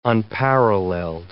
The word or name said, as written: unparalleled